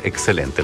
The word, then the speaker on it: XCLNT: Así se llama, pero en el disco lo escriben con las letras XCLNT. Se pronuncia excelente, el nuevo disco de Los Tres.